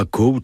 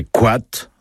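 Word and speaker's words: koad: Alors, pas coat, hein. Non, c'est quat. Même en français, quat, c'est ouaté, c'est le même mot pour la prononciation, c'est pas coat ni coat. Hein, on évite, on parle breton, pas grand breton. Quat, c'est quat.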